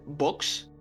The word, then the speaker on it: Vox: Vox. – That's right. Actually, Spanish people don't distinguish between B and V, so it's you pronounce it Vox, like boxing, more like that. But yeah, it's fine both ways.